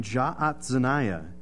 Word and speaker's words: Jaazaniah: The other issue that would take place here is Shaphan has a son named Jaazaniah. It's a fun name to say. Anytime you have double A's together, you actually do pronounce them individually.